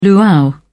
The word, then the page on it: luau: British English